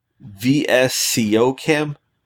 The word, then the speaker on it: VSCO Cam: Snapseed and VSCO Cam. I don't know if you say that or. – Yeah, that is actually how they say it.